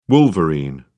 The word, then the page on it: wolverine: British English